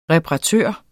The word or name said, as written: reparatør